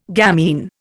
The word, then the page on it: gamine: Indian